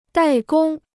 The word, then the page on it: 怠工: dài gōng